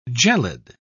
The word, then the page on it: gelid: British English